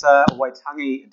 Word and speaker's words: Waitangi: It's Waitangi Day, pronounced correctly.